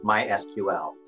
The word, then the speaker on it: MySQL: And in their specification, they inform you how to pronounce the name of their software. And it is to be pronounced MySQL.